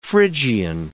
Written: Phrygian

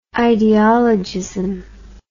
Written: ideologism

